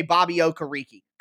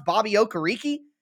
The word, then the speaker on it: Okereke: You don't want to go inside with DeForest Buckner and Darius Leonard and Bobby Okereke, which for some reason, no commentators on television know how to say Bobby Okereke. Come on, guys. You get paid so much money, more money than I get paid, and you can't pronounce Bobby Okereke?